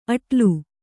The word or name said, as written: ಅಡಲು